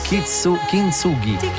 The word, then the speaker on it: Kintsugi: Vedi, vai avanti mesi a dire un titolo, Kitsugi, Kitsugi, Kitsugi, poi scopri dal testo che è Kitsugi. Kitsugi la pronuncia esatta. – Senza la N? – Kintsugi. – Kintsugi. Ah, ok, Kintsugi.